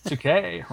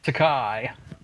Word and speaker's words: Takei: Takei rhymes with toupee. Not Takai, as in sky.